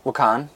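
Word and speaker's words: Waukon: And so it's walk-on. I say walk-on. Walk-on? How do you say it? – Wakan.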